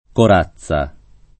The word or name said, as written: corazza